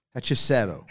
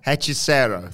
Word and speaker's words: Hechicero: Hechicero. – Hechicero, thank you.